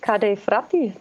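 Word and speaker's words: Ca' dei Frati: Wie spricht man, Achtung, Werbung, bekannter Wein, Cat Fratti aus? Chat Fratti oder Cat Fratti? – Also du bist ja schon mal ganz gut, Kade Fratti. Das hört sich doch perfekt an.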